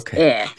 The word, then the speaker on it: Eyre: E-Y-R-E is air.